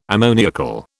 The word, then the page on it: ammoniacal: British English